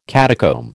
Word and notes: catacomb: American English

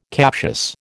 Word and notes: captious: American English

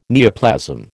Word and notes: neoplasm: American English

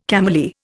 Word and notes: cameleer: Indian

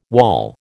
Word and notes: wawl: American English

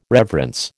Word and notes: reverence: American English

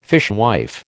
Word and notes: fishwife: American English

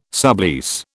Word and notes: sublease: British English

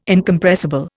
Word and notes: Incompressible: Indian

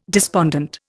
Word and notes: despondent: Indian

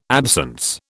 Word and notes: absence: British English

